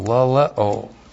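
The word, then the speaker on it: laleo (λαλέω): L-A-L-E long O, laleo.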